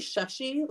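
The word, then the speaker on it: Shashi: So it's actually Shushy, like a short U kind of sound. Shushy.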